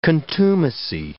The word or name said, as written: contumacy